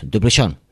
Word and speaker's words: Desplechin: que dirían en francés, Desplechin, ¿no? Porque la S no se pronuncia, creo.